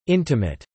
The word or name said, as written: intimate